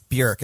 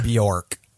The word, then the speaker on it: Björk: Yeah, she says it rhymes with jerk. – Oh, does she? – That's what she says. She's like, it's actually, it's Bjork. It rhymes with jerk, not Bjork.